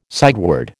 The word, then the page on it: sideward: American English